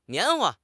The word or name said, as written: 棉花